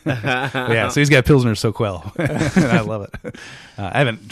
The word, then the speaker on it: Soquel: It's Soquel. – Q-U-E-L.